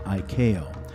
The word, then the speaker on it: ICAO: They go by the initials ICAO, pronounced ICAO, which is part of the United Nations that deals with air traffic.